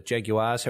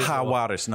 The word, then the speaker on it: Jaguares: Obviously, picking up the Jaguares or the Jags or the Jaguars, however you do pronounce Juarez. – That name. Jaguares. – Jaguares, no G.